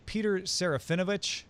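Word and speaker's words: Serafinowicz: Do you say his name Peter Serafinovich? Is that right? – Sounds right.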